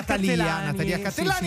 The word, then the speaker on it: Cattelani: Natalia Cattelani. Sì, sì, non sbagliamo l'accento.